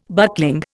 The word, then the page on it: buckling: Indian